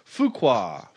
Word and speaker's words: Fuqua: Sorry, I said it wrong last time. Last movie. Fuqua.